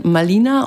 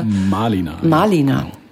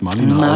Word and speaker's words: Malina: Also einen Roman geschrieben, glaube ich, einen autobiografischen Malina und aber sehr viele Malina. Tatsächlich spricht man das Malina aus.